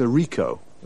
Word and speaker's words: Ricoh: It's a Ricoh. – Ricoh? How do you spell that? – R-I-C-O-H.